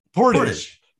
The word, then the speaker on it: portage: Portage. – That's emphasis on the first syllable.